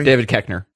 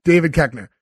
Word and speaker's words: David Koechner: David Koechner. – David Koechner.